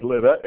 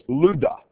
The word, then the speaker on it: Lydda: Now, the pronunciation of that word, it looks like Lydda, it's Luda.